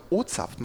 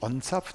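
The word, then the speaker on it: O'zapft: Ich glaube OZAPT, man sagt nicht ONZAPT.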